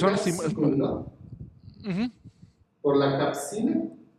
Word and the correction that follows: capsaicina: La, la capsaicina. Ajá, ok, bueno, discúlpenme mi pronunciación, no lo pronuncié adecuadamente, pero se supone que de, ahí producimos o se produce un medicamento, por lo consecuente inhibe otras sustancias, ¿no?